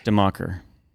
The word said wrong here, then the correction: Dominion: Close. Not Democker. Dominion.